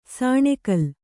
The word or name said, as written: ಸಾಣೆಕಲ್ಲು